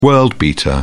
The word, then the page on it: world-beater: British English